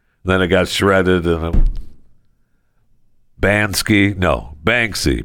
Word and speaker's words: Banksy: I keep calling it Bansky is not correct. It's Banksy. Okay. Bansky, right? What is it? It's Banksy. Banksy is how you say his name.